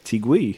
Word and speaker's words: Teague: He has a silent U in his name, and I appreciate that. He doesn't even say it phonetically. – Good thing for that, then. – Yeah. Jeff Teague.